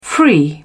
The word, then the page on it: free: British English